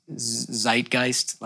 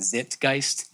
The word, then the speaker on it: zeitgeist: I was giving a talk the other day and I mispronounced the word zeitgeist many times. I kept pronouncing it zitgeist.